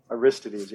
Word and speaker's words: Aristides: How would you pronounce that if he was a Greek playwright? You would pronounce it Aristides. – Aristides, yeah.